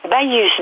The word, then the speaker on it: Baïus: Baïus, B-A-I-2-L-U-S. – Baïus. Je vous ai laissé le prononcer comme ça m'évite de me faire gronder par Jean-Pierre Huguin pour pas avoir réussi à le prononcer correctement, on prononce le S, tout ça, voilà.